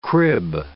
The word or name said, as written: crib